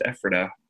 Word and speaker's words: Ephrata: So they're out of Ephrata, but I know some people say Ephrata. So it's Ephrata from what I've heard.